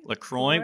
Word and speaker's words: LaCroix: LaCroix? – LaCroix.